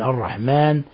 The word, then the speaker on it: الرحمن: ومن الأخطاء التي قد تقع وخاصة عند العاجم تفخيم لفظ الميم من الرحمن، الرحمن يقول لك الرحمن.